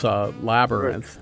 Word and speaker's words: labyrinth: But I picked labyrinth. – Officially three syllables, labyrinth.